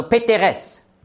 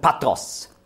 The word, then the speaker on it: Patros: Jetzt wissen Sie auch, warum so viele ägyptische Wörter in den Büchern E-E-E heißen. Ja? Nefertete und was auch immer. Immer E-E-E. Also Peteres. Ja? Aber im Hebräischen wird das ausgesprochen Patros.